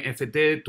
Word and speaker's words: NFT: No, gracias a ustedes por invitarme y bueno, emocionada de ver a muchas más personas uniéndose a NFT, así es como lo pronuncian, ¿no? – Así Genial. – mismo, NFT, NFT en español.